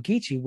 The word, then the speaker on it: Geechee: And so you'll hear, like, even with the word Geechee, which, you know, is spelled with a K, in its other form of K-I-S-S-I, is pronounced G-E-E-C-H-E-E. Because of that alternating the K and G sound.